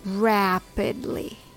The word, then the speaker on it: rapidly: Put it together and you get rapidly. Say it with me slowly. Rapidly. Now, try saying it a little faster. Remember the stress is on the first syllable, rap.